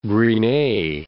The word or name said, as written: Gris-Nez, Cape